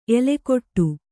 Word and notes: ಎಲ್ಲೆಗೆಡು: ellegeḍu

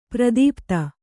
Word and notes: ಪ್ರದೀಪ್ತ: pradīpta

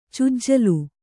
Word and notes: ಚುಜ್ಜಲು: cujjalu

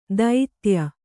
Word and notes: ದೈತ್ಯ: Kannada; daitya

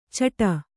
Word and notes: ಚಟ: caṭa

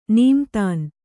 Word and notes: ನೀಮ್ತಾನ್: nīmtān

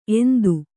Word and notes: ಎಂತ: enta

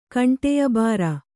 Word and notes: ಕಂಟಿಯಬಾರ: Kannada

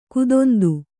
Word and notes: ಖೇದಾಂತ: khēdāmśa

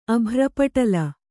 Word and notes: ಅಭ್ರಪಟಲ: Kannada

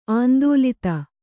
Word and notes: ಆಂದೋಲಿತ: āndōlita

